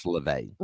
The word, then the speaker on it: LaVey: when you have the head of the Satanic Church, Anton LaVey, the announcer pronounced it wrong. He said LaVey, it's LaVey.